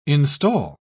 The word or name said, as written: install